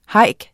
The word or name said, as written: hike